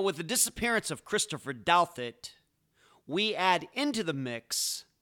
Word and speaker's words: Douthat: Christopher Douthat, and yep, that's how you pronounce his last name, had a rough upbringing.